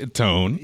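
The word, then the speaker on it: Etón: So you bought the Eton. Is that how you say it? And there's an accent over the O. – Accent over the O, so let's go with that. Eton.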